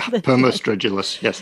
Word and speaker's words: Permostridulus: But then you describe, I think, this like cricket-like creature, Permostridulus. Am I saying that right? – Yeah, Permostridulus, yes.